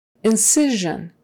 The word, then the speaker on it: incision: Incision. Incision. The C is pronounced like an S. The S is pronounced like a Z-H, J, J sound. Incision, incision. Stress on that middle one.